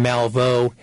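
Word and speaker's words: Malveaux: It's Greg, G-R-E-G, then Malvo, M-A-L-V as in Victor, E-A-U-X as in X-ray, .org.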